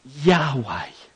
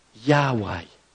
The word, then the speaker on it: Yahweh: and they are pronounced Yahweh. Yahweh.